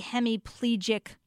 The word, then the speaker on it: hemiplegic: What is a hemiplegic migraine? I don't even know if I'm saying that correctly, but what is that? – Yeah, you're saying it perfectly.